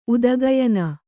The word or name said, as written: ಉದಗಯನ